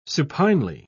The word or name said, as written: supinely